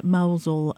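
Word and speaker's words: Mousehole: a place called Mousel, which is spelled mouse hole, but you say it Mousel, and that's a typical Cornish fishing village.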